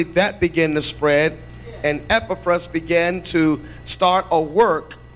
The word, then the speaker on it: Epaphras: And in that revival in Ephesus was a young man, and I pronounce his name Epaphras.